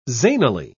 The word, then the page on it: zanily: British English